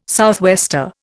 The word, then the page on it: southwester: Indian